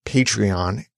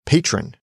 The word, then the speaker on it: Patreon: For example, people often hear Patreon as patron. They think you might be just mispronouncing the word patron when you say Patreon.